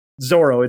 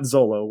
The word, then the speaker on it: Zoro: They say it's Zoro. It's not Zolo.